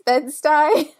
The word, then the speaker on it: Bed-Stuy: I had to look up how to say that because I know I say it wrong sometimes. So I think it's Bed-Stuy.